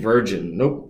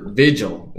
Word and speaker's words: Vigil: Linebacker Nick Virgin. Vigil. Yeah.